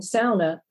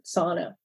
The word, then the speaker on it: sauna: There's a local pronunciation, sauna, that's different than how most Americans pronounce this word, sauna.